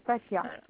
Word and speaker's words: Fatiha: HEN, Fatia, FATIHA.